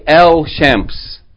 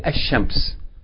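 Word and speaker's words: ashams (الشمس): Sun letters, and they have the rule like the word shams, meaning if they have aleph lam, you don't say el shams, but rather that sun letter is going to be doubled and the lam is going to be merged into it, so you're not going to pronounce the sound of el, you're going to say ashams.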